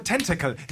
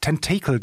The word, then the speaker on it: Tentacle: Okay, äh, der für Tentacle. Ich habe extra nochmal geschaut, wie es ausgesprochen wird, weil ich habe immer Tentacle gesagt, aber es heißt Tentacle. Tentacle.